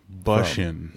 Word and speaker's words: Bussian: Bussian. – Yeah. – That's how it does rhyme with Russian.